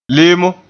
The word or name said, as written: lemo